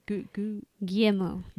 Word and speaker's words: Guillermo: Guillermo del Toro.